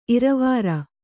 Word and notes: ಇರವಾರ: iravāra

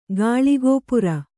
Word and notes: ಗಾಳಿಗೋಪುರ: Kannada

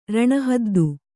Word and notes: ರಣಹದ್ದು: Kannada; raṇa haddu

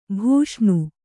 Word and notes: ಭಾಷಣ: bhāṣaṇa

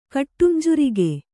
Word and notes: ಕಟ್ಟುಂಜುರಿಗೆ: kannada

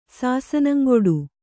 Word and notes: ಸಾಸನಂಗೊಡು: Kannada

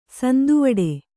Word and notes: ಸಂದುವಿಡು: sanduviḍu

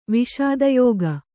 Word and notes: ವಸುಧಾಯೋಗ: vasudhāyōga

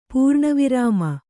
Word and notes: ಪೂರ್ಣವಿರಾಮ: pūrṇa virāma

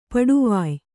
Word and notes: ಪಡುವಾಯ್: paḍuvāy

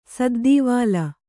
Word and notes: ಸದ್ದೀವಾಲ: saddīvāla

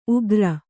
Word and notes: ಉಗ್ರ: ugra

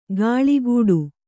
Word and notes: ಗಾಳಿಗೂಡು: gāḷigūḍu

